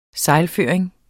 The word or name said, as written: sejlføring